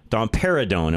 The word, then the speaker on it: domperidone: The drug, by the way, is, I'm probably going to mispronounce this, but domperidone. Am I saying that right? – That's right.